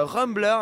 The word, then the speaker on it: RMBLR: Moi mon morceau c'est Rumbler. Alors ça s'écrit R-M-B-L-R. T'as noté? – J'ai noté, c'est bon. – R-M-B-L-R.